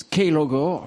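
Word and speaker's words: Klogr: Enfin, ça s'écrit Clogre, mais ça se prononce Keylogre.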